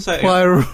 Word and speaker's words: Poirot: Hercule Poirot. Yeah, you don't pronounce the T in Poirot, so it doesn't quite work.